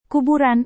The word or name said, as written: kuburan